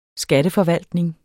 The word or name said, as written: skatteforvaltning